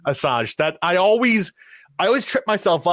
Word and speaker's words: Asajj: I know that it is pronounced Asajj, but that does not look how it's spelled.